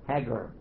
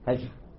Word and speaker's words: Hajar: Only we called her Hagar. And that's okay, because in Cairo, Egypt, they also say Hagar. But we say Hajar. Different way to pronounce the G.